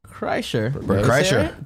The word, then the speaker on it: Kreischer: Bert Kreischer.